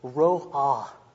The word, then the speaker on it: ro'ah: that word for noise is ro'ah. Ro'ah. R-O-O-A-H, if you were to pronounce it in English.